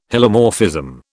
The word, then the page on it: Hylomorphism: British English